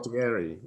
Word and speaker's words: Condottieri: Condottieri, or however you say that. – Condottieri, yeah.